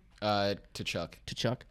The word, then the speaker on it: Tkachuk: Like we had like I think the Huberto Tkachuk, is that how you say his name? – Tkachuk.